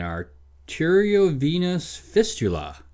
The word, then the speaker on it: arteriovenous fistula: An arteriovenous fistula, said it right the first time, forces them to detour through the heart, where cardiac arrest must be induced to avoid turbulence.